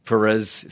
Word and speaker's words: Perez: Became our public radio paparazzo, tracking and stalking the various celebrities here, and you did catch up with Perez, is that how you say his name? – It is. – Hilton. – It is Perez Hilton, right.